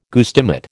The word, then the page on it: guestimate: American English